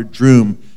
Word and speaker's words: Droom: Not Dr. Doom, but Dr. Droom to rhyme with broom that you sweep with, D R O O M.